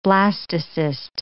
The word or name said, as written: blastocyst